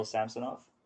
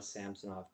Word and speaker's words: Samsonov: And I wanted to say it like that, and then I had to teach myself to say it's Samsonov.